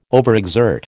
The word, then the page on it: overexert: American English